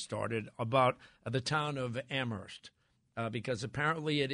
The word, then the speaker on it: Amherst: I went to school out in Western Mass many years ago, and we were always told by the locals that the H is silent in Amherst. – Yep, it's Amherst.